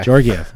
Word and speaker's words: Georgiev: Georgiev. There is a soft G in there.